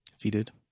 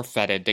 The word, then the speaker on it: fetid: I think it typically is like fetid. Like F-E-T-I-D. – Fetid. Okay. – Or fetid. I guess it depends on if you're British.